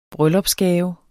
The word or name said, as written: bryllupsgave